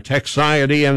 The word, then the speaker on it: Techxiety: It's spelled T-E-C-H-X-I-E-T-Y, Texiety,